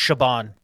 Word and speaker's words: Siobhan: So that's pronounced, that's a weird Gaelic name. It's pronounced Siobhan.